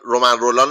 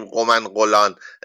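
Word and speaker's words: Romain Rolland: حالا ما تو فارسی که ر رو ر میگیم یعنی مثلا رومن رولان رو نمیگیم قمن قلان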